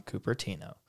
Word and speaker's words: Cupertino: Give some live commentary and check out all that new from Cupertino.